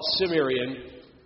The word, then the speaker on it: Cimmerian: Gomri, Cimmerians, it's a hard C. We would see that, we'll call it Cimmerian, but it's a hard C. It should be Cimmerian.